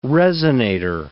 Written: resonator

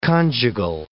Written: conjugal

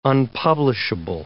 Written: unpublishable

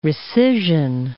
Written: recision

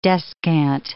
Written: descant

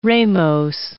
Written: ramose

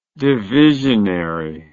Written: divisionary

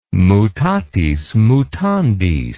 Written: mutatis mutandis

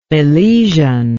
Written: milesian

